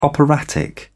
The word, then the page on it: operatic: British English